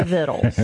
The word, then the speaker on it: victuals: It's spelled victuals, right? – But if you're from Appalachia, you say Vittles.